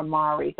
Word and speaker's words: Mari: So you can go to Mari Smith, M-A-R-I. I'm not sure if it's pronounced Mary or Mari. Say Mari, M-A-R-I.